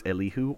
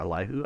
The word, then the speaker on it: Elihu: it's Elihu or Elihu. I respond to any of those.